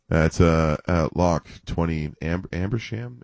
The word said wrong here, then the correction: Ashburnham: Ambersmish. Yeah, yeah. We don't know how to pronounce it. – Ashburnham. – That's the one.